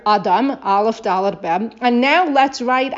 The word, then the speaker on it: Dalet: Okay, it's really like Dalet. Even though we say Dalet, it's really Dalet.